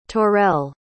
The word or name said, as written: torrell